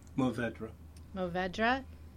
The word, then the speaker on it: Mourvèdre: Mourvèdre.